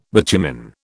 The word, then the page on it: bitumen: British English